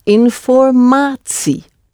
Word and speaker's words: informatie: So the T is actually pronounced like a T, but TS, informatie, informatie. – But it isn't spelt that way. – No, it isn't. It's spelt INFOR and then MATIE, informatie, ati, really. Pronounced informatie because it's from French origin.